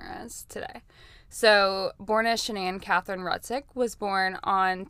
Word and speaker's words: Shanann: Also, I hear people saying her name is Shannon, Shannon-Ann, a lot of things. I heard her in her videos say her name is Shanann. – Shanann. – So that is how. – And he says Shanann. – And he says Shanann as well when he's referring to her.